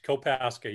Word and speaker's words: Kopaska: So no further ado, we got Mr. Jeff, is it Kopaska? Did I pronounce that right? – Correct. Kopaska. Yep.